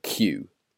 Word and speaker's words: queue: Well, in the United States, they call it a line, and we call it cue, which is spelt Q-U-E-U-E, to wait in the queue.